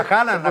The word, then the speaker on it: Haaland: No, a Haaland, a Haaland. – Se pronuncia Haaland.